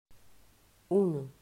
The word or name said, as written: Uno